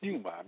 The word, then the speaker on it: pneuma: The Greek word for spirit is pneuma, pronounced pneuma,